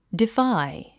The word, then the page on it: defy: difǽi; デファイ